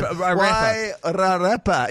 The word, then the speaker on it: Wairarapa: I did think of a good rap name, the Wairarapa. Wairarapa would be good for my rap name. – Wairarapa. – Yeah, Wairarapa. – Please explain it some more. – Wairarapa. – It's hard with the critics' pronunciation. Wairarapa.